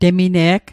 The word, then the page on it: temine'g: Mi'gmaq